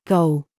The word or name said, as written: GIG